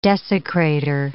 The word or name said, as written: desecrator